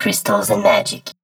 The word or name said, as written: Chrystals and Magic